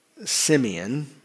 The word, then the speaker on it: Simeon: His name, we pronounce it in English, Simeon. But in Hebrew, it would have been more like Shemaian. And it's from the word Shema.